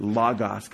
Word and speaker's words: logos: So modern New Testament Greek speakers pronounce it logos because there's two omicrons, not two omegas.